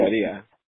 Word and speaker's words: Ikaria: And I was on an island in Greece called Ikaria. I think it's how you say it in English. It's I-K-A-R-I-A. R-I-A. I know how to say it in Greek. – How do you say Ikaria? – Greek is good.